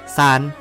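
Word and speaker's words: ซ าน (三): ออกเสียงว่าซานซาน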